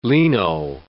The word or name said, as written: leno